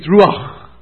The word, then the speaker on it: Ruach: In fact, the Old Testament word for Spirit is Ruach.